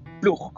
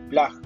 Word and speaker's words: PLUG: el, plug, como dicen, o el Plag, como algunos pronuncian, que es el Peruvian Linux User Group.